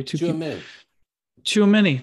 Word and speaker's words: Tchouaméni: Tchouaméni.